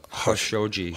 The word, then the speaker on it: Khashoggi: It's Khashoggi. – Khashoggi. – Gesundheit. – Yeah. – The only one pronounces it right then is Anderson Cooper. – Yeah? – He pronounces it Khashoggi.